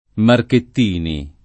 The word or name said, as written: Marchettini